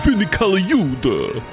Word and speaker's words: Vinny Colaiuta: And the non-drum machine programming, the live drums are by ex-Frank Zappa guy Vinny Colaiuta, I think is how you pronounce his name. – No, it's Vinny Colaiuta.